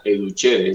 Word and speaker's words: educere: Pero el más profundo y el que propone es educere, se escribe educere con C, educere, y se pronuncia educere, es del latín.